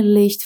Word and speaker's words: Licht: Prestando atenção à pronúncia de Licht, que tem esse som chiadinho. Ohne Licht Fahrrad zu fahren.